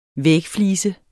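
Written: VÆGFLISE

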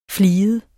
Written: fliget

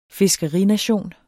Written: fiskerination

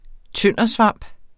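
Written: tøndersvamp

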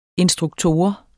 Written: instruktor

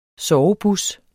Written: sovebus

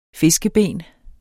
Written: fiskeben

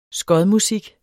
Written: skodmusik

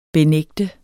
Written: benægter

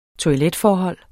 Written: toiletforhold

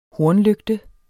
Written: hornlygte

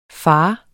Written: fars

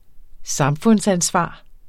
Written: samfundsansvar

